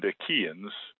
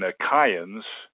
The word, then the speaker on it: Achaeans: I've just pronounced it as an E sound, Achaeans, but in the original Greek, it would have been Achaeans, and many scholars will say Achaeans.